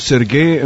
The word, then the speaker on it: Сергей: Да, но Сергей е много готино, защото е с две стрички, ударението е на втората.